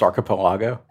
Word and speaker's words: archipelago: Isn't it pronounced archipelago? – Not according to this gentleman. It was archipelago. – All right. – And I've always said archipelago. – I think your emphasis is on the wrong syllable if you pronounce it that way.